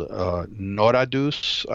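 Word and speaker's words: Noratus: But we went to a town called Noradus, I believe it's pronounced, and it's very near to Lake Sevan, which is the largest lake in Armenia.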